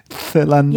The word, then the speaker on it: Phalangiella: Phalangiella. Yeah, it's really hard. – No, I think you did great.